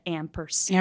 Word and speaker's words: ampersand: He just put out an EP called Caroline and Me with the, how do you say it? It's like not and spelled and, it's like the ampersand.